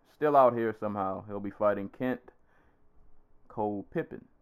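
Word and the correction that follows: Kauppinen: I probably butchered that. – Kopinen, I think his name is.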